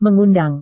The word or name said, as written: mengundang